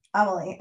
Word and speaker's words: Amelie: Because I was thinking it was like, I was pronouncing it in my head like Amelia, but Emil, what were you saying? – Amelie.